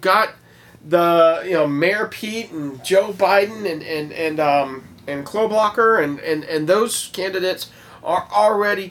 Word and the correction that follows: Klobuchar: Is it Kloblocker or Kloblocksler? – I thought it was Klobuchar.